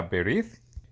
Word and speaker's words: berith: Y hay personas que creen que este es un derivativo de raíces en el idioma hebreo, ya que la palabra pacto en hebreo se pronuncia berith.